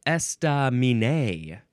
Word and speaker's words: estaminet: Now, is that esta? It looks like those first three syllables, none of them get emphasized more than the others. Estaminé. It is spelled E-S-T-A-M-I-N-E-T. Don't forget about the T.